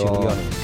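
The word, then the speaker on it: Marčiulionis: Marciulionis. Perquè que aquesta C es pronuncia com una TX.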